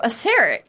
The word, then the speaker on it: Aseric: And then the fifth edition module that he appears very prominently in was like, nope, that's not how you pronounce it. – So Aseric. – Aseric, which Aseric. Makes sense if you look at the way you spell it.